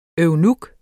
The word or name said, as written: eunuk